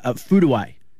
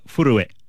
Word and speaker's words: Furue: I've got to get the pronunciation right here. Japan's Ayaka Furue is how... – Pretty good. – Is pretty good. – Because it's spelled F-U-R-U-E, and I was watching the coverage going, Furue.